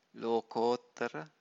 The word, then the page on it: lokuttara: Pali